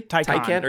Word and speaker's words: Taycan: Taycan or Taycan.